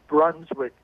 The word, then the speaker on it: Brunswick: Brunswick, Georgia. Am I saying that right, watching Free Speech TV? – It's Brunswick, Georgia. – Brunswick, I'm sorry.